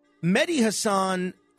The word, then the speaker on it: Mehdi Hasan: Mehdi Hasan, I think, is the correct pronunciation of his name.